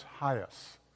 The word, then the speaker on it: HIAS: And second, because they were part of a congregation that supported an organization called, I think it's pronounced Hias. H-I-A-S, the Hebrew Immigration Aid Society.